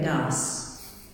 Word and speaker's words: Ars: in that part of France they actually pronounce the S. So that meant he was the Curé d'Ars.